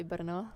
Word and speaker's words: Brno: It's like in English, you'd say Brno. Okay, Brno. – In Czech, I would say Brno.